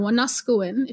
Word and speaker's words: Wanuskewin: So my master's thesis is focusing on plains archaeology, of course, specifically around the Apimahau Valley, which is the valley where Wanuskewin or Wanuskewin, if you say it properly in Cree, is situated.